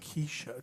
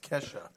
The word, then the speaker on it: Kesha: It's not Keisha, it's Kesha.